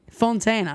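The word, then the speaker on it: Fontaines: Well, then I like idols, but I hate Fountains DC. – Yeah, people don't really... Fontaine, actually, that's the name.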